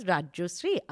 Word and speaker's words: Rajyasree: So my name is pronounced Rajasri, but because I am sadly in Delhi, people all say Rajeshwari and whatnot. So just to make life simpler, I also say that my name's Rajasri. And that's a thing. So that's why it's spelled as Rajasri.